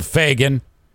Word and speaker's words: Fagen: Now, last week's JC's Tales from the Road, I talk about how Donald Fagen from Steely Dan got super pissed off because JC did not call him Donald Fagen. He called him Donald F rhymes with bag in.